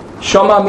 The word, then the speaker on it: Shema: It's called the Shema, spelled S-H-E-M-A in English, but it is in Hebrew pronounced the Shema.